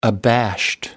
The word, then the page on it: abashed: American English